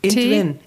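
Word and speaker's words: Int-Veen: Entwen. – Entwen. – Und wie schreibt man das? – E-N-T-V-E-N. – Nein. – I-N-T? – Nein.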